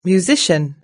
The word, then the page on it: musician: British English